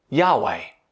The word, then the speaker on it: Yahweh: Now, whenever we see those capitalized letters, L-O-R-D, it's code for God's personal name. In Hebrew, it's nothing more than a series of consonants. Y-H-W-H. And the closest we get to pronounce that name is Yahweh.